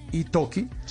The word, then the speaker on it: Italki: Es I-T-A-L-K-I, Itoki, que es una plataforma para compartir.